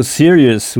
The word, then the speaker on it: serious: She looks so serious, oui, avec « s-e » en début de mot qui se prononce « si ». Donc « serious », S-E. R-I-O-U-S.